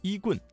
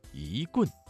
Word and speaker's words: 一棍: 一棍降妖的一字，一字在四声字前变成了二声，所以正确的读音不是一棍，而是一棍，一棍降妖。